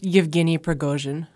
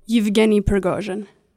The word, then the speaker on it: Yevgeny Prigozhin: It's pronounced Yevgeny Prigozhin. – Yevgeny Prigozhin. – Yeah, that's right.